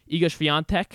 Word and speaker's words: Iga Świątek: Iga Świątek, did I say that right? – Yes, that's correct.